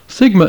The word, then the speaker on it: sigma: Sigma is pronounced s, as in the word sand.